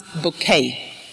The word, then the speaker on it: Bucket: And the main character there is Mrs. Bucket, pronounced Bouquet.